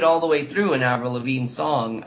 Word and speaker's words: Avril Lavigne: You, Avril Lavigne makes five wishes you got in there. Is that how you say it? – Avril Lavigne, yeah.